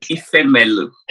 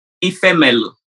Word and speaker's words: Ifemelu: Ifemelu. – Stop it right now. Say it again. – Ifemelu. – Ifemelu. Okay, that was the lead. That was the lead response. Ifemelu.